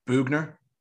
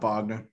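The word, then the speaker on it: Boughner: Forgot to mention that, but we're gonna move on to our next team, the San Jose Sharks, coached by Bob, how do you say it? I always forget. – Bugner. – Bugner, not Bogner. Okay.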